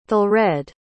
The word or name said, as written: thelred